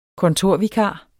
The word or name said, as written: kontorvikar